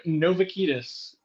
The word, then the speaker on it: Novakidis: Steve Novakidis, which I can promise you I've heard every imaginable pronunciation.